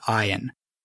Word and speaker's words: Iain: They dated in high school, and his name is Ian Hunt, and it's spelled I-A-I-N. He pronounces it Ian.